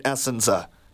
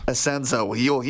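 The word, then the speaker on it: Essensa: Bob Asenza, is that how you pronounce it? Because I always pronounced it Essenza. – Asenza.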